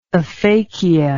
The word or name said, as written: aphacial